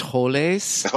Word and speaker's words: Holes: It's pronounced Holes. It's not. – That's right. It is California. – It's not. – You know, he was at Contra Costa, was where he's from. So it's Holes.